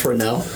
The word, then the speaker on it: Renault: It's Renault. It's pronounced Renault.